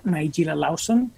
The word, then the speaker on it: Nigella Lawson: Y se llama Nigella Lawson, que se escribe Nigeya con dos L's, Lawson, el apellido es L-A-W-S-O-N,